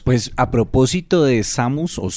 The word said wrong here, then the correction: Seamus: ¿cómo es? – Seamus. – Seamus.